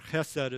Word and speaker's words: chesed: Mercy, or the Hebrew is chesed, or chesed is how you hear me pronounce it.